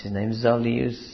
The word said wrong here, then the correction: Zizioulas: Zizioulas, who is an Orthodox writer.